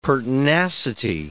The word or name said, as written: pertinacity